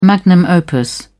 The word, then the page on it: magnum opus: British English